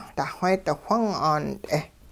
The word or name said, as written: duxweedi-whung 'aant'e